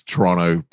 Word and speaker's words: Toronto: And yeah, there's a pizza joint near my house that is Toronto pizza. It's spelled Toronto. – How is it? – T-R-O-N. Yeah, T-R-O-N-O. Toronto. No, yeah, if you say the T, that's how people know you're not from Toronto.